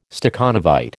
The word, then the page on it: Stakhanovite: American English